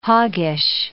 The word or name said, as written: hoggish